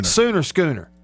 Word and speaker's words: Sooner Schooner: Boomer Schooner? – Boomer Sooner. – Sooner Schooner. It's not Boomer Schooner. It's Sooner Schooner.